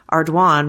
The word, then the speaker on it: Ardoin: I live in Lake Charles where Pizzolatto grew up and that is a really common surname, but it's pronounced Ardoin, rhymes with swan.